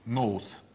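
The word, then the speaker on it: Нус (νοῦς): Нус. Нус читается.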